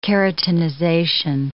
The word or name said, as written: keratinization